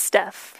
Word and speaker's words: Steph: It's Steph. Steph rhymes with Jeff.